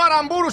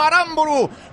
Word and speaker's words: Aramburu: Real Sociedad, porque ha comenzado el partido, lo hace por mediación de Zubeldia, que busca un apoyo arriba, maneja desde atrás Aramburu, que así me ha dicho Marco, que se correcto pronuncia, no Aramburu, sino Aramburu.